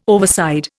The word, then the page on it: overside: Indian